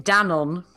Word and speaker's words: Danone: In Europe, we call it Danone, which I'm sure is incorrect. But yeah, very... – That is the more correct way to say it. It is a French company, so yes.